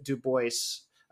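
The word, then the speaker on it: Du Bois: Spelled, you might think it's Dubois. Many people say that. I said it the first time, of course. W.E.B. Dubois. That's right. William Edward Burghardt Dubois. – Oh, Burghardt. – And he very pointedly pronounced it Dubois as a way of sort of claiming his Americanness and his American blackness, and not alluding to maybe the European aristocratic feel that it might give.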